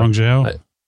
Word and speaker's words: Guangzhou: Guangzhou.